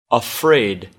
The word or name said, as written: afraid